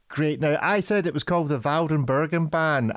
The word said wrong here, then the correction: Waldenburgerbahn: I have no idea if I've pronounced that even appropriately correctly. Would you like to give us the non-Scottish accented? It's the Waldenburgerbahn. But say that again. The Waldenburgerbahn. Yeah, yeah, I'm not going to be able to say it like that.